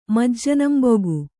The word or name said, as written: ಮಜ್ಜನಂಬುಗು